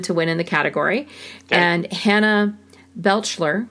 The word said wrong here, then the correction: Beachler: uh, Beachler, I'm sorry if I said Hannah's name wrong, won for Best Production Design.